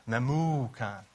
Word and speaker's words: Memucan: His name really is pronounced Memucan.